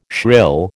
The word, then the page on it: shrill: American English